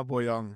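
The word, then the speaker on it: Bojang: Jetzt bei GC, vielleicht können wir das schnell vorwegziehen, du aus GC Intimus, schießt auf einmal, wie spricht man den Namen eigentlich aus? Boyang. – Ich hätte gesagt, Adama Boyang, ganz einfach.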